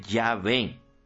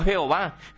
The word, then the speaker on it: Yahvé: Y ustedes saben que en hebreo, los hebreos, el alfabeto hebreo no tiene vocales, son solo consonantes, J, W, H, W, y se pronuncia científicamente Yahvé. Si ustedes me escuchan decir, mi house is beautiful, ustedes se van a reír y van a decir, mire ese cura ahí haciéndoselas, ¿sí? Y no, es my house is beautiful. Lo mismo, J, W, H, W es Yahvé, científicamente, no Jehová. Jehová está mal pronunciado.